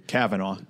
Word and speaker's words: Kavanaugh: The new Supreme Court justice nominee, you know, who has to be approved, is Brett Kavanaugh. I think I'm saying that right. But, yeah, I basically talked a bit about on the podcast with Luke. – Kavanaugh. – Kavanaugh, okay. Well,